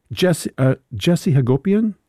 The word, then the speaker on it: Jesse Hagopian: And Bill, Jesse Hagopian, am I saying his name correctly? – Yes.